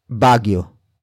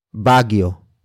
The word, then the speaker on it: Baguio: And it was at Baguio. Baguio.